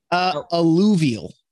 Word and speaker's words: Alluvial: The new Alvuel album. Did I say that right? I've never. – Nope. – Alvuel. – Alluvial. – Alluvial. See? – Yeah.